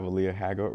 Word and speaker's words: Aaliyah Haggard: We have Aaliyah Haggard with us.